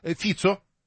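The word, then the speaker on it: Fico: Ваше сообщение читаем, Игорь, 650-й, ФИЦО, ударение на первый слог, но при этом почему-то подчеркнул букву О.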